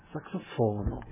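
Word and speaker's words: saksofono: Da hören Sie immer auf der vorletzten Silbe bitte betont. Verda Saxofono.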